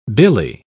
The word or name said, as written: billy